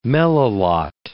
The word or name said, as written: melilot